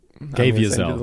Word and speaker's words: Caviezel: Okay, Jim Caviezel.